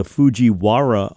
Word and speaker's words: Fujiwara: We come back talking to Barry Keim, our friend, LSU health climatologist, about the Fujiwara effect. Did I say it right? – You did.